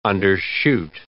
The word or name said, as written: undershoot